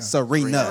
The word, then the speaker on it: Serena: Is Sabrina or Serena? – Serena. – Oh, Serena. – Like Venus and Serena.